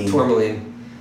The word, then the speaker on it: Tourmaline: So after Tourmaline, am I saying it right? – Tourmaline.